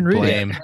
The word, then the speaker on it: Blame!: There's one called Blame. – Oh, that sucks. – You ever heard of Blame? – Blame? B-L-A-M-E? – Like Blame? – B-L-A-M-E. Exclamation point. – I believe it's pronounced Blam.